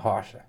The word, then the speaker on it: Rocha: Wagner Rochelle versus Gordon Ryan, and that's how you pronounce it. Gordon. No. Wagner. Wagner Rocha.